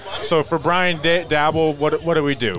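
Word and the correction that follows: Brian Daboll: Brian DeBall.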